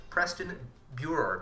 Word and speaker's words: Preston Buehrer: Preston Buehrer. Did I say that right, Preston? – Yeah, you got it. You got awesome. It. That's pretty close.